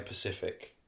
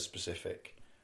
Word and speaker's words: specific: People say Pacific instead of specific.